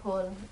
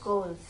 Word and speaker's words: Coles: Not Coles. It's Coles. C-O-L-E. – Oh, Coles. Okay. We have K-O-H-L-S in the U.S.